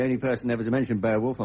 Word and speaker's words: Beowulf: Ladies and gentlemen, the classic work of Anglo-Saxon literature, Beowulf, or as the Anglo-Saxons would have pronounced it, Beowulf,